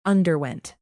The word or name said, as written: underwent